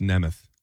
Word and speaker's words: Nemeth: It's pronounced Nemeth. Nick Nemeth.